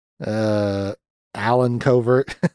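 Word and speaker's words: Allen Covert: Adam Convert, or how the fuck you say his name? Alan Covert. Wow, I really fucked that.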